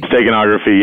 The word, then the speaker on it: steganography: Steganography, is that it? Am I pronouncing it right? – Steganography, yes. If I'm pronouncing it correctly, yes. Steganography.